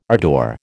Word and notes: ardour: American English

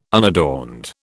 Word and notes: unadorned: British English

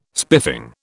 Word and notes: spiffing: British English

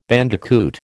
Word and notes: bandicoot: American English

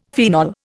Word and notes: phenol: Indian

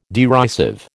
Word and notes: derisive: American English

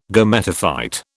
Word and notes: Gametophyte: British English

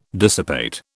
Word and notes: dissipate: British English

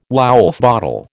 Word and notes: Woulfe bottle: American English

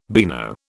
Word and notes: beano: British English

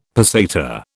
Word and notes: peseta: British English

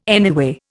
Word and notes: anyway: Indian

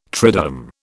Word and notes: triduum: British English

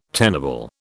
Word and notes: tenable: British English

